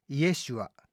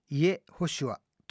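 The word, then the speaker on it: イ エ シュ ア (ישוע): ヘブライ語的に発音するとイエシュア、あるいはイエホシュアとなり、その語源はヘブライ語でヤーシャーという言葉です。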